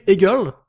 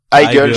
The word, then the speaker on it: Heigl: Catherine Egel ou Catherine Hegel, comment ça se oh, prononce Heigl, je crois.